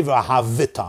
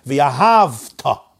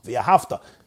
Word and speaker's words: v'ahavta: I'm not supposed to say v'ahavita. I'm supposed to say v'ahavta. V'ahavta.